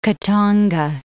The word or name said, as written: Khatanga